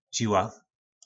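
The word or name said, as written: Žiła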